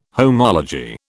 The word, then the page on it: homology: British English